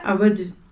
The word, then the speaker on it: इवज: इवज का लफ्ज जो यहाँ पे इस्तेमाल हुआ है ना, इसकी एप्लीकेशन थोड़ी सी प्रोनाउंसिएशन के फर्क से बदल जाती है। टेढ़ेपन को कहते हैं, एक होता है अवज और एक होता है इवज।